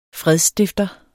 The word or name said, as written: FREDSSTIFTEREN